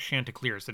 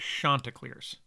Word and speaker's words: Chanticleers: It's spelled C-H-A is how it starts. So a lot of people were saying Chanticleers. I learned that it was not Chanticleers, that it was Chanticleers.